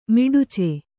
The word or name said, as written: ಮಿಡಚಿ